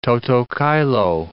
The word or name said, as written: toto caelo